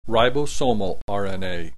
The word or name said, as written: ribosomal RNA